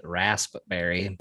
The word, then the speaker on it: raspberry: I think it's spelt raspberry, but I don't think I can pronounce the P in there.